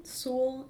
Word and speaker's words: Sewell: So my name is Allison Sewell, and my last name is S-E-W-E-L-L.